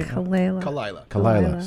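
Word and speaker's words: Khalyla: Bobby Lee and Kalayla. Am I saying it right? – Kalayla.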